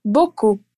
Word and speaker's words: beaucoup: Hoje a nossa atenção especial é para a letra P que está lá no final da palavra, que é o exemplo que eu te dei aqui. Reparou que esse som não é pronunciado? Pois bem, a regra geral é seguir esse exemplo e ignorar praticamente todas as consoantes finais em francês. Então repete comigo a palavrinha que significa mais em francês: beaucoup. Muito bem.